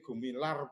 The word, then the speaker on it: Gumilar: ini Mas Gumilar, oh, Gumilar, ini Gumilar berarti terbacanya.